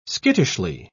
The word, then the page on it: skittishly: British English